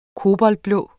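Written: koboltblå

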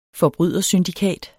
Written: forbrydersyndikat

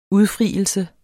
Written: udfrielse

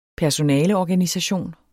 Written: personaleorganisation

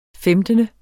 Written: femtende

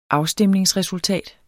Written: afstemningsresultat

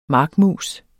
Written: markmus

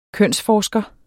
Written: kønsforsker

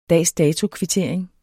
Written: dato

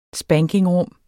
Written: spankingrum

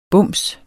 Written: BUMSENS

